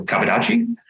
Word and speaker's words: Kabudachi: In Japanese dialect, it would be faster than that because there's no pauses. So Kabudachi.